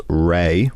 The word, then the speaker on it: Raye: What I've been listening to a lot in the last few days was an artist called Ray, which is spelled R-A-Y-E, who is from Croydon in London.